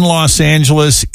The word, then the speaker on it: Los Angeles: Mary says the G in Los Angeles is pronounced as an H.